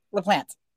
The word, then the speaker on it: Laplante: Laplante.